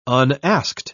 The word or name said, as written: unasked